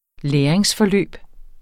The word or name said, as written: læringsforløb